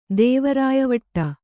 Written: ದೇವರಾಯವಟ್ಟ

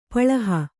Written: ಪಳಿಹ

